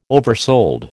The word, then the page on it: oversold: American English